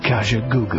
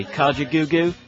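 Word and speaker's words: Kajagoogoo: What's interesting is that in America, you say Kaja Goo Goo, but in England, we're called Kaja Goo Goo.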